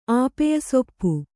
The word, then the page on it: ಆಪೆಯಸೊಪ್ಪು: Kannada